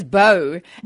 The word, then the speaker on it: Beaud: that was French, and you oh. spell it B E A U D. So you can only imagine how it came out in South Africa. Bode, Boyd, Beat, but Charnique.